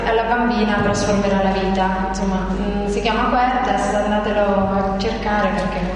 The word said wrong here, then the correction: Cuerdas: Cuerdas, lei mi dice come si scrive.